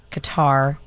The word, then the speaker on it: Qatar: Qatar or Qatar? – Qatar.